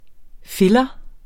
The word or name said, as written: filler